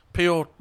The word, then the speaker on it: Piotr: It's like Pietor or something. – Piotr.